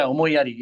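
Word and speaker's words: Omoiyari: Yeah, and so now Omoi Yadi, I hope I'm saying that correctly. How should I say that? – Yeah, Omoi Yadi, you got it. You got it right.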